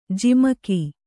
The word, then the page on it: ಝಮಕು: jhamaku